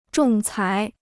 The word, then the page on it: 仲裁: zhòng cái